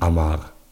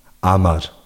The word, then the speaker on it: Amar: Genau, das ist halt ein bosnischer Name, der mir sehr gefällt, was aber leider in der deutschen Aussprache liegt zu wenig Betonung auf dem R. – R, gell. – Ja, und das geht ein bisschen unter. – Ja, und das ist einfach traurig. Und dann, es ist nicht Amar. Amar. Das ist die richtige Aussprache.